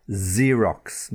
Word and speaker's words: Xerox: And then when you're copying or making a copy, you've got a Xerox machine or Xerox paper, Z sound, but it begins with the letter X.